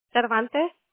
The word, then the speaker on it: Cervantes: Cervantes. – Okay. Can you spell that for me, please? – C-E-R-V-A-N-T-E-S. – Okay. C-E-R-V-A-N-T-E-S.